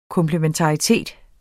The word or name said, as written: komplementaritet